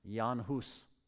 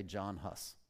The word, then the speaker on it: Jan Hus: His writings greatly influenced the next guy, properly pronounced Jan Hus. We might say John Huss.